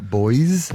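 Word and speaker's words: Boyz: I don't think you pronounce the Z there, mate. The Plastic Cup Boys.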